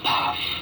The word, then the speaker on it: Pfaff: P-F-A-F-F spells Pfaff, pronounced in Thai, Paff.